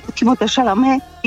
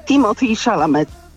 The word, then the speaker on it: Timothée Chalamet: No i powiedział, że jako osoba, która jest po mamusi Amerykaninem, po tatusiu Francuzem, akceptuje absolutnie obie wymowy swojego nazwiska, czyli możesz o nim mówić i Timothée Chalamet i Timothée Chalamet. Wszystko mu jedno.